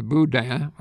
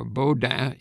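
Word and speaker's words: bodhrán: It's spelled peculiarly and pronounced even more peculiarly. It's spelled B-O-D-H-R-A-N. And I looked it up on Wikipedia, and it's pronounced boudin or bowden. Either way is correct.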